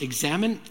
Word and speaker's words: examen: It's based on an ancient practice called the prayer of examen. Some of you would pronounce it examen. Either one is fine.